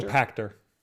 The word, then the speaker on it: Pachter: Dr. Serkan Toto, CEO of Kantan Games, that is not how you pronounce that, and Michael Patcher, analyst. What now? – Pachter. Michael Pachter.